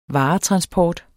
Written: varetransport

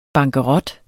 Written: bankerot

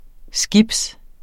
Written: skib

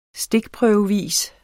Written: stikprøvevis

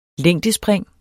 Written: længdespring

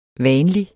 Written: vanlig

